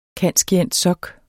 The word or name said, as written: cand.scient.soc.